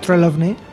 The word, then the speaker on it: Trelawney: Trelawney.